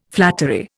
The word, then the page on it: flattery: Indian